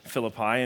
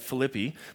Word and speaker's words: Philippi: You come from a place that call it Philippi, but we're going to call it Philippi, okay, for our series.